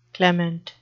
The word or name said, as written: clement